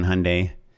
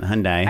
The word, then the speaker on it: Hyundai: I've pitched on Hyundai, which is why I know it rhymes with Sunday. And it's not Hyundai.